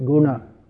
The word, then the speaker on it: guna: guna. Say it again. No. Wrong. Look and see what it says. Everything is to be pronounced. It's not Hindi. In Hindi you say gun. In Sanskrit, guna.